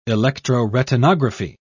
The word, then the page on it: electroretinography: British English